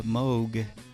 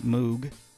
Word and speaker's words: Moog: It is the Bob Moog. Or as likely a lot of people pronounce it, Moog.